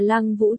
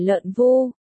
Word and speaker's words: Lăng Vũ: Đã nói tôi là Lăng Vũ chứ không phải Lợn Vu, ở đây làm ơn phát âm cho chuẩn vào.